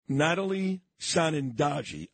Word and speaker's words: Natalie Sanandaji: Natalie Sanandaji, I think. Did I say it correctly? – Yes.